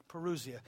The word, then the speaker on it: parousia: Spell parousia. – Parousia. P-A-R-O-U-S-I-A.